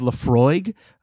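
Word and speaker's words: Laphroaig: Yeah, it's going to be, I think it's pronounced Lafroig. Right, yeah. It's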